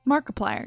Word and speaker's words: Markiplier: that it was Markiplier. I didn't know that was how you pronounced it. – Markiplier. Yeah, like multiplier.